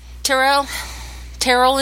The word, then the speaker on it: Terrell: Terrell and Cheryl, it rhymes, we finally decided.